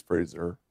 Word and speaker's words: Fraser: It's Fraser. – Fraser. I think you shave with a razor. – Oh, as in, so it's not Fraser like Fraser Crane.